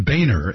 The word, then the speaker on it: Boehner: John Boehner, as he says his name, is the congressman who is the Speaker of the House.